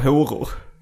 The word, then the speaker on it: horor: Ja, det var ett intressant uttal av horor. – Horor? – Det var väldigt mycket å. Alltså så, A med en ring över. – Håror.